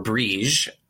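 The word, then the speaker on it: Bríd: And Brigid, or Brige, as she might be pronounced in traditional Gaelic, was connected to the origin of Keening.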